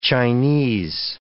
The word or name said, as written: Chinese